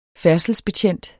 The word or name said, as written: færdselsbetjent